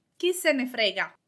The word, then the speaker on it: chi se ne frega: The first one is chi se ne frega. In spoken language, you might hear it pronounced as one single word, chi se ne frega.